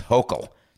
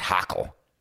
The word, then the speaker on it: Hochul: It's Hochul, not Hochul. Did I get it right this time? Is it Hochul? Hochul? Come on, get it right for me. It's Hochul.